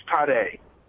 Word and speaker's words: Tade: Am I pronouncing it right, T-A-D-E? – It's Tade. – Tade. Tade. – Yes.